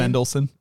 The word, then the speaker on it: Mendelsohn: Mendelsohn.